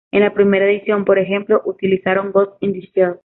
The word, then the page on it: utilizaron: ispanskii